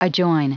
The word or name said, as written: adjoin